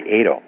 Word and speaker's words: Ado: I say Ado, like potato.